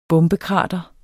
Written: bombekrater

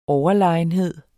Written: overlegenhed

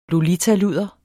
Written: lolitaludder

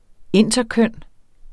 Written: interkøn

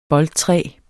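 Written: BOLDTRÆET

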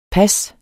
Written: pas

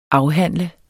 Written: afhandle

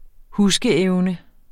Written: huskeevne

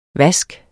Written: vaske

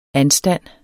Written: anstand

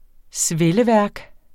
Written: svelleværk

